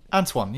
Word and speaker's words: Antoine: That's not how you say his first name, though, is it? Antoine, is it? – Antoine, yeah. – Antoine, well, that's close enough anyway.